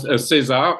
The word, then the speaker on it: César: Is it the César? Am I saying that correctly? César Awards? – Yes, César. The César are the French Academy Awards.